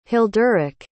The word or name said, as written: hilderic